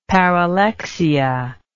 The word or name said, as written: paralexia